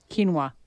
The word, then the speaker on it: quinoa: Or quinoa. How do you say that word again? – Quinoa.